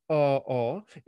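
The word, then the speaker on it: aa: It's A-A. A Hawaiian word pronounced R-R.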